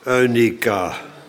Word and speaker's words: Eunice: en in uw moeder Eunika. Eunice, want EU is Grieks en dan spreek je uit als een ui.